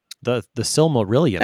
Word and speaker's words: Silmarillion: The Silmarillion. – There you go.